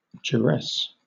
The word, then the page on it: duress: British English